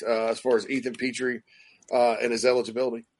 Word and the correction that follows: Petrie: We got to get you saying it right. Petrie, God bless it. bless it. I know. I know this. I've been corrected. I've been corrected many times, and I, I, I just forgive me, guys.